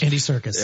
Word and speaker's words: Serkis: By the way, Andy Serkis, is his name pronounced? – Andy Serkis.